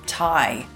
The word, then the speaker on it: Tighe: So today I have managed to seconda from way down south the very effervescent and extremely intelligent Steve Tighe. Steve has a silent G in his name, by the way, so you'll find it as T-I-G-H-E